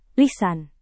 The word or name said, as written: lisan